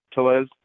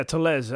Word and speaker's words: Tellez: Did I say that right, Rowdy? – Yeah, Tellez. – Yeah, Tellez.